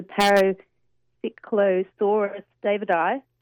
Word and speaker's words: Paracyclosaurus davidi: is a Paracyclosaurus davidi.